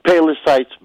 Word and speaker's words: Palos Heights: Palos Heights. Did I say that right? – Palos Heights, man.